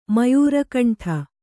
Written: ಮಯೂರಕಂಠ

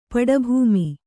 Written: ಪಡುಭೂಮಿ

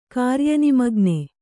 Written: ಕಾರ್ಯನಿಮಗ್ನ

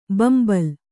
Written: ಬೆಂಬಲಿ

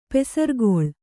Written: ಪಸಿರ್ಗಲ್

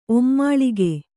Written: ಒಮ್ಮಾಳಿಗೆ

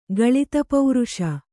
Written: ಗಳಿತಪೌರುಷ

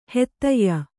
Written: ಹೆತ್ತಯ್ಯ

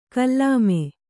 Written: ಕುಲಿಮೆ

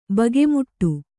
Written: ಬಗೆಮುಟ್ಟು

